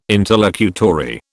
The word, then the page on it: interlocutory: British English